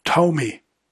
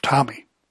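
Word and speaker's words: Thome: It's pronounced Tomy. By the way, Jim Tomy is a great name for a dog. It's less confusing than Tomy, which really does sound like a mispronunciation of Tommy.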